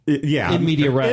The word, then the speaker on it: in media res: I'll still say in media res, because no one will know what the hell you're talking about if you pronounce it correctly. – The pronunciation is something defective in Medea's race. – Yeah. – But in the what? In media res. – In media res.